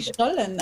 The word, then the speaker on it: Stollen: It's actually Stollen.